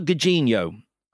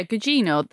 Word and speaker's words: Gugino: Of Carla, now it's Carla Gugino. Am I right? That's the pronunciation I've gone for. – Yeah, Gugino, that's what Gugino, right, great.